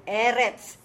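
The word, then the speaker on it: Eretz (ארץ): Eretz. – That sadi is a ts, ts, as in cats. Ts, sound. Eretz.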